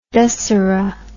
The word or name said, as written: dusserah